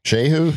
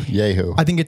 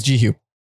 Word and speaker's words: Jehu: Drive Like Jehu? Yehu. – I think it's Jehu.